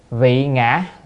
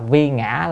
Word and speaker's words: vị ngã: Vị ngã là vì con. Cái này phải đọc là vị nha, đọc vi là không đúng nha. Nếu mà đọc vi ngã là Phật làm con á, là Phật mà làm con, là làm thành con là không được.